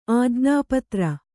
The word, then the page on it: ಆಜ್ಞಾಪತ್ರ: ājñāpatra